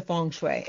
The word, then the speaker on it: feng shui: So how did you get into feng shui? And am I saying it properly? – You are saying it properly.